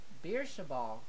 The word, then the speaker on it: Beersheba: 1917, World War One, the Battle of Bursch Beersheba, Beersheba, yeah, I think I said that right, known as the last successful cavalry charge in the history.